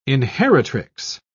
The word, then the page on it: inheritrix: British English